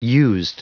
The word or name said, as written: used